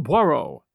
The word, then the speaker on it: Poirot: his name being Hercule Poirot. And yes, I checked, and that's how his name is usually pronounced.